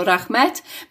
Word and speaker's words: Rahmat: And in addition, I think worth mentioning is our correspondent mentioned in her newsletter how she met a Russian in Bishkek and asked him to pronounce Rahmat correctly in Kyrgyz. And he said it was all the same to him, whether it was Rahmat or Rahmet, meaning thank you, because to him, Kyrgyz and Kazakh were one language.